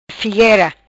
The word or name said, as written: Figuera